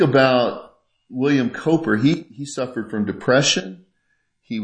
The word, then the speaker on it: Cowper: It's spelled Cowper, C-O-W-P-E-R, but his name was Cowper.